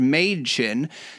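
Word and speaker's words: Mädchen: But they named her Madchen because she says that's how it's pronounced, Madchen.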